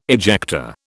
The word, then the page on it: ejector: British English